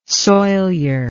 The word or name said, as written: soilure